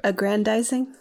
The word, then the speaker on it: aggrandizing: How do you pronounce that? – Aggrandizing. – Thank you.